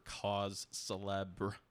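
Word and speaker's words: cause célèbre: And Brock Stassi is kind of, he gets the Matthew Rizzotti, Darren Ruff Award for cause celeb. How do you pronounce that? – Cause celeb. – Cause celeb. The R-E is silent, right? – Yes.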